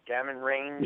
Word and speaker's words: Gammon: These gammon, I think I'm saying that right, gammon range. – Yeah, the gammon ranges.